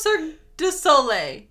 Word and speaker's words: Cirque du Soleil: Did I say it wrong? – I thought it was Cirque du Soleil. – Soleil. Yeah, it is.